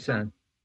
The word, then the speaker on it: Busan: It is Train to Busan. Am I pronouncing that correctly? – Busan. – Busan. Busan.